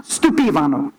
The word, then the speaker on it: stupivano: The word for astonished is stupivano. Stupivano, right? Stupivano. There we go. I had the emphasis on the wrong syllable.